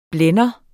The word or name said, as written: blænde